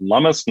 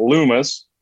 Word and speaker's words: Lummis: You pronounce it Lummis, not Loomis. Lummis like hummus.